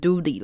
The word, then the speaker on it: duty: 可是这边 duty 的 t 其实是小 d 的音，所以也不是完全的 duty。 duty 的话听起来会怪怪的，所以你们会把它念成小 d，duty 这样子。所以如果你念太长，或是念得太强调那个 d 的话，就变成 duty 了。